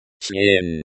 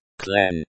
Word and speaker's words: член: The Russian word for member is член. – Pronounced clen.